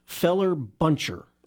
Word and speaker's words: feller buncher: Feller buncher. Like, it's a hyphenated word. F-E-L-L-E-R. Oh, like you fell a tree.